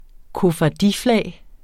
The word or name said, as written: koffardiflag